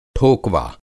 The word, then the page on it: ठोकवा: Hindi